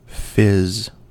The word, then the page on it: fizz: American English